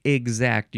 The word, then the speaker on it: exact: So when you say exact, your pronunciation at like a GZ.